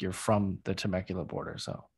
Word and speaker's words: Temecula: So you live in Temecula.